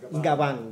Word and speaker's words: Gabán: G-A-B-O-N? – G-A-B-A-N. G-A-B-A-N. – G-A-B-A-N. – Yes. Gabán. Gabán, yes.